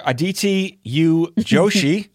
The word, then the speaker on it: Aditi U. Joshi: Dr. Aditi U. Joshi.